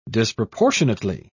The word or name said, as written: disproportionately